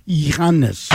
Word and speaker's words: Giannis: And I can tell you, it's a silent G. – Antetokounmpo. – That's how we do it in Athens. In Athens, we, you know. – You're like kindred spirits. – It's like this. It's Giannis. Giannis.